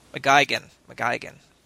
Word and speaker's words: McGuigan: But this is going to be directed by Sherlock's Paul McGuinn. So, McGuigan, pronounce it right.